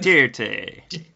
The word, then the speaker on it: Duterte: Also known as Rodrigo Duterte. I think I'm pronouncing that right. – Duterte.